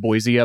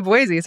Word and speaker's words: Boise: Also, we call it Boise up there, just so y'all know, and that's how it should be pronounced.